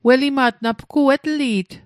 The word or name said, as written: welima't